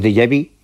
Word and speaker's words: déjà vu: lo que llamamos déjà vu, que eso, mira que bien lo pronunciaba. – Sí, muy bien.